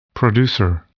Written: producer

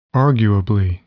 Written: arguably

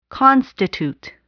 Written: constitute

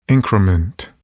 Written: increment

